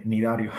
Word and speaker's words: cnidario: Eh, pues con, la C no se pronuncia, es con, N, nidario.